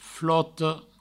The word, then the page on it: flota: floto; Provençal